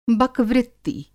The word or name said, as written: बकवृत्ति